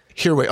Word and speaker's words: Hirway: Last name is Hirway. – Hirway. Oh, so the H is not silent.